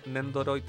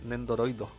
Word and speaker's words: Nendoroid: Eh, Juaco Damasco no se dice Nendoroid, se dice Nendoroido.